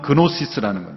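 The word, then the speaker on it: gnosis: 이것은 헬라어 그노시스라는 영어에서는 G 발음이 이제 생략이 돼서 헬라어는 그노시스라는 거예요. 안다라는 거예요.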